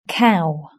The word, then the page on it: cow: British English